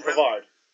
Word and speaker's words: Pavard: Yeah, Benjamin Pavard.